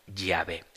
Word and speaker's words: Yahvé: Ciertamente, las especulaciones y los estudios más recientes determinan que la pronunciación más próxima, más probable, sea Yahvé.